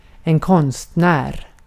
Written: konstnär

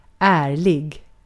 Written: ärlig

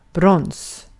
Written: brons

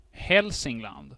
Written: Hälsingland

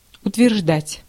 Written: assert

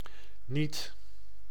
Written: niet

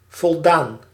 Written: accomplished

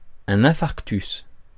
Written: infarctus

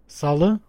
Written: tisdag

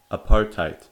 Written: apartheid

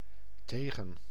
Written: with

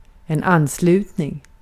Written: katılım

